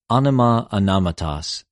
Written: ὀνομα,ὀνοματος

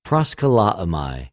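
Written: προσκαλέομαι

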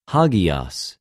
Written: ἁγιος

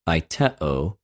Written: αἰτεω

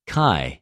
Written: και

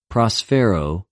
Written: προσφερω

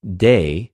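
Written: δεῖ

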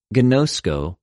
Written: γινωσκω

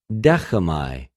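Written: δέχομαι

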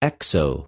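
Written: ἔξω

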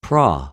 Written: προ

